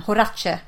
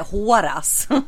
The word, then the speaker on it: Horace: Horace, säger jag då. – Jag tänkte säga Horas. – Så att Horace.